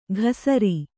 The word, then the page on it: ಘಸರಿ: ghasarui